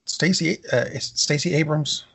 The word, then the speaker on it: Stacey Abrams: There actually is a considerable amount here, because Stacey Abrams, am I getting the name right? – You got it.